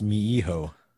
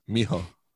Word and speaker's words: mijo: Me, Joe. It's pronounced mi-hijo. – Mijo.